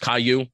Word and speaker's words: Caillou: It is pronounced Caillou, like the children's character.